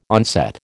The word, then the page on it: onset: American English